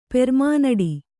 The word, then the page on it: ಪೆರ್ಮನಡಿ: kannada